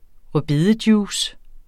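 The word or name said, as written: rødbedejuice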